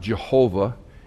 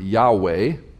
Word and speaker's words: Yahweh: Unfortunately, there's no J sound in Hebrew. It's not Jehovah, it's the word Yahweh. We don't know how to pronounce Yahweh. There's no vowel pointing on it. It's Y-H-W-H, roughly transliterated into English.